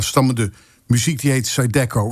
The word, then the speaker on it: Zydeco: Tenminste, dat spreken we zo uit. Maar je moet het eigenlijk uitspreken als Les Haricots. En als je dat nou maar genoeg creool uitspreekt, dan krijg je waarschijnlijk Les Haricots.